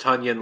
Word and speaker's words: Tonyan: No, the Packers said his name is pronounced Tonyan like Funyun instead of just onion.